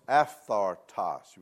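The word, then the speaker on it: aphthartos (ἄφθαρτος): Now, this word imperishable in verse 23 is pronounced aphthartos. You want to say that with me? Aphthartos.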